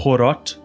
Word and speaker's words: Heorot: Eu vou começar aqui contando a história do Heorot, ou Horot, pelo que eu vi na aquelas letrinhas, né, de, de pronunciação uhum.